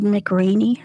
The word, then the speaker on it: McRaney: Played her first husband, Gerald McRaney.